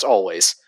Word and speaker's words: Alvvays: The it, the W is two V's, so it looks like Alvvays, but it is pronounced Always.